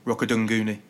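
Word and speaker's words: Rokoduguni: Rokodunguni.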